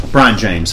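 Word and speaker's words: Brion James: Byron James. – Okay. – Brian James. That's his name. Brian. It's spelled. It's like B-R-I-O-N.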